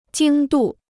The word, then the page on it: 经度: jīng dù